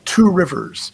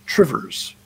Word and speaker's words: Two Rivers: Them, like Two Rivers. You don't say it Two Rivers; it's Trivers.